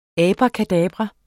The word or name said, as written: abracadabra